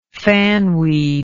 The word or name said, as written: fanweed